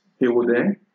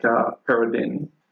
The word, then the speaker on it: Perodin: My last name in French is pronounced Péaudin, but here most people say like Péaudin. – Yeah, because it's spelled like P-E-R-O-D-I-N.